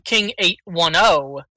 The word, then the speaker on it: King 810: So, King 810, pronounced King 810, which is the area code for Flint, Michigan, is a new metal band from, oh my god, Flint fucking Michigan.